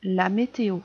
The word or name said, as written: la météo